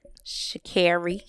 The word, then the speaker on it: Sha'Carri: Sha'Carri.